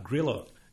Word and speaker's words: Grilo: Grilo.